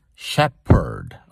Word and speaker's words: shepherd: Those of you learning English as a second language or a foreign language, remember it's shepherd with a P, okay? Don't be deceived by the PH and give an F sound.